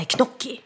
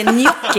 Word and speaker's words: gnocchi: Det är jättemånga som säger till exempel, nu ska vi göra eh, knocki. Och det, det är gnocchi utlöst det.